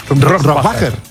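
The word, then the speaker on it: Rohrwacher: Rohrbacher.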